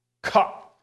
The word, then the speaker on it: Kaf: Each of these eight verses in Hebrew begin with the 11th letter in the Hebrew alphabet, Kaf, which sounds close to the English word cough. And that's exactly how you pronounce it. You have to cough. Kaf.